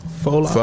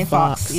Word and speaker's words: faux: The joke that's spelled F-A-U-X? – Faux locks? – Faux. – I never pronounce it like that. – Some people say fox.